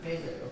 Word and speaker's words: Taizé: Second thing, someone who's more worldly traveled than I, Taizé service, and if you've been there, am I pronouncing that correctly? It's a town in France. Taizé, okay.